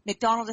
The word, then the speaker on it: McDonald: McDonald is spelled M-C-D-O-N-A-L-D.